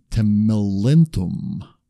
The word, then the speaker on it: Lolium temulentum: Then there's a particular weed called Lolium temulentum.